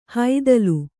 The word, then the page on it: ಹೈದಲು: haidalu